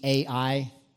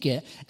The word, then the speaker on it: Ai: When we see AI, we think of artificial intelligence, right? And it's not pronounced AI, it's literally just pronounced I, which is near Beth Aven, to the rest of Bethel, and told them, go up and spy out the region.